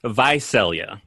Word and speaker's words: Visalia: Visalia.